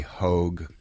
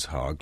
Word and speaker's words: Hogg: They sometimes would say Hogue, thinking Hogg would be rude, but it is Hogg, like pig.